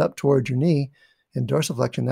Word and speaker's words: dorsiflexion: There's lots of stability there if you're in dorsiflexion.